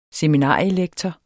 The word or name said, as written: seminarielektorer